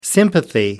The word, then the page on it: sympathy: American English